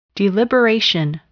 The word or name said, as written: deliberation